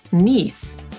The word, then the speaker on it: Nice: Tak samo jak słowo nice, zapisujemy angielską nazwę miasta Nicea, tylko że oczywiście wielką literą, i wymawiamy to jako niece. To taka sama wymowa jak siostrzenica albo bratanica, tylko że tamto słowo z kolei zapisujemy jako niece.